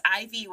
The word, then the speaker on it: Aivy: And her first name is spelled A-I-V-Y. So it's Ivy with an A in front.